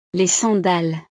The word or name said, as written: les sandales